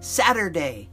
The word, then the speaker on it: Saturday: Capital S-A-T-U-R-D-A-Y. Night. That was an old song. Saturday. And it's got a T in there, but we pronounce the T like a D.